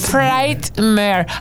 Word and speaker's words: Frightmare: Durante un panel en la convención Texas Frightmare, ¿cómo se dice eso, eh? – Frightmare.